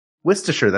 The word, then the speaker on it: Worcestershire: Worcestershire. That's how you pronounce it.